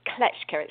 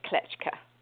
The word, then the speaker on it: Kleczka: My last name is Kleczka. It's Kimberley Kleczka.